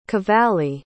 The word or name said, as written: cavalli